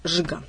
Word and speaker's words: джига: А самый известный танцевальный жанр – это джига. Но произносится она как жига.